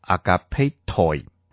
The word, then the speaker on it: agapetoi: 这个称谓希腊文读作 agapetoi，和合本翻译作亲爱的弟兄啊。